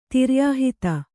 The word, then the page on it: ತಿರ್ಯಾಹಿತ: Kannada